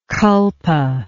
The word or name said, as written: culpa